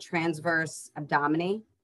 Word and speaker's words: transverse abdominis: and transverse abdominis.